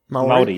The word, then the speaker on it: Maori: So German, Hindi, Korean, Maori. Is that how you say it? – Yep. Maori. Yep. That's right.